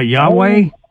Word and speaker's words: Yahweh: You talking about Yahweh? – That's what I mean. That's the way we say it is Gentiles or whatever. But it's spelled YHWH, no vowels.